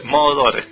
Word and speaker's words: Moroder: Así es, Moroder.